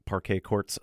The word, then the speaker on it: Parquet Courts: But this one, just assume parquet, as I try to pronounce it phonetically, as in the type of wooden flooring that you would see at a basketball court. And then quartz, as in the court, I said.